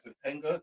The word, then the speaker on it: Topanga: Topanga.